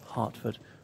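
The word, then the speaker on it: Hertford: Hartford, spelt with an E, but pronounced in a Hartford, because, you know, so their symbol is a type of deer, because in Dutch, hart is a deer.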